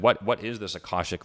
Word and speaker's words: Akashic: Akashic record. I hope I'm pronouncing that correctly. – Oh, Akashic, yes.